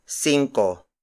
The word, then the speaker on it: singko: Cinco is spelled as S-I-N-G-K-O. In Spanish, this would have been spelled as C-I-N-C-O. But this is Tagalog, and we spell words how it comes out of our mouths.